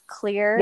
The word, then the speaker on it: Xlear: And in the holistic, natural airway world, the really popular one right now is Clear. – Yeah. – It's spelled X-L-E-A-R.